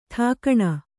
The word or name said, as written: ಠಿಕಾಣೆ